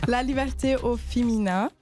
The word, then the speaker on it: La Liberté au Féminin: Also es war schon relativ gut, aber es heißt La Liberté au Féminin.